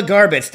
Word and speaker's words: Garbacz: And then we'll, so Angela Garbacz, is that the way you pronounce it? – That's actually perfect.